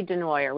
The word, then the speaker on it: Desnoyers: It's a French say Desnoyers, we just say denoyer. We've anglicized it.